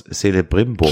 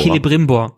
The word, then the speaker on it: Celebrimbor: Celebrimbor. – Celebrimbor.